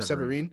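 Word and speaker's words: Séverine: Like when they're at the bar and he's talking to how do you say her name? – Severine. – Thank you, Severine. – Severine.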